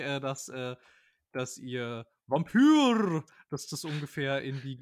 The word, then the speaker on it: Vampyr: Ich bin mir sicher, dass es Vampyr heißt. – Vampyr. Alles klar, so wird das ausgesprochen. Ja, es heißt Vampyr.